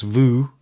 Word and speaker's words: WUU: Disgusted, Voo, which is WUU, but it's pronounced Voo. There's quite an interesting backstory to that name.